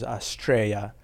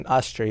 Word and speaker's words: Astraea: It's actually in the Greek methodology is Astraea, and Astrea is one of the ways to pronounce it, and I really like that.